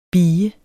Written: bie

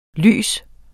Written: lys

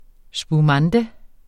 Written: spumante

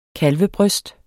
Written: kalvebryst

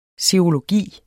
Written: SEROLOGI

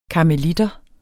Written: karmeliter

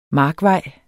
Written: markvej

